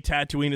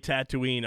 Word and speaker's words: Tatooine: the way Tatooine is supposed to be pronounced, he does it. when I heard him say Tatooine, I was like, that's how it's supposed to be pronounced. Nobody else should say it. Tamara Morrison is the only person that can say Tatooine from now on.